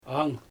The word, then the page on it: aŋ: Xyzyl